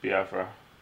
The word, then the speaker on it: Biafra: Or, oh, people are trying to bring back Biafra. I said that wrong, did I? – Biafra.